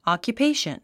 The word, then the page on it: occupation: ὰkjupéiʃən; アキュペイション